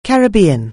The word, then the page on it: Caribbean: anglais